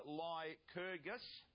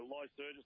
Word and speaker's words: Lycurgus: Yeah, I'll have to take a Lycurgus, Lycurgus, whatever. It's okay. – It's ancient Roman, ancient Greek. I'm not sure. – Yeah, I think it's Kerr in the middle, pronounced okay.